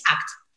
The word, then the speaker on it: ACT: Acceptance and Commitment Therapy es ACT, se pronuncia. – Ah, ACT.